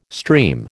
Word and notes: stream: American English

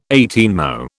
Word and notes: eighteenmo: British English

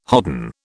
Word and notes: hodden: British English

